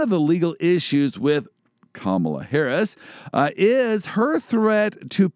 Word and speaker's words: Kamala: Mamala. – So it rhymes with Kamala. – Kamala, Mamala. So if I just think of mom, stepmom, Mamala Harris, Kamala Harris, that's how to pronounce her name.